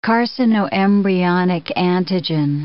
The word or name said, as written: carcinoembryonic antigen